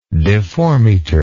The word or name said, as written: deformeter